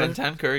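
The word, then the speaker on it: Bentancur: Ben Kanker or whatever. – Ben Tanker, yeah.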